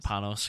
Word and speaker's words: Panos: Panos, because there's no nya over the end.